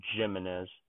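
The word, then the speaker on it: Jiménez: And so another good framer, like, I'm looking this up so that I don't insult the guy, but according to Baseball Reference, I'm supposed to pronounce it Jimenez. So it's a soft G.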